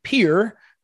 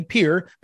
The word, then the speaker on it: Pierre: And she lived in Pierre, that's how you pronounce it, you kids that are practicing, Pierre, P-I-E-R-R-E, Pierre, South Dakota.